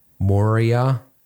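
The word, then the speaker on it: Maurya: And another book I'd recommend as well is Running Lean by Ash Moria. That's how you say his name.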